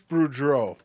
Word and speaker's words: Boudreau: Boudreau. – Okay, Bruce Boudreau.